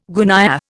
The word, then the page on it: gunyah: Indian